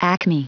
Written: acme

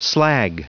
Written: slag

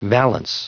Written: valance

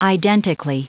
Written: identically

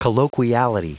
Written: colloquiality

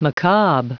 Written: macabre

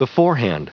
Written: beforehand

